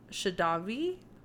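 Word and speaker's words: Skjervøy: But at 2 years old, his family moved to an island called Shadavi. and that's not how it's spelled, but like I googled the Norwegian pronunciation and that was what it sounded like. It was okay. Like Shadavi, but it's like S-K-J-E-R-V-O with a dash Y-A.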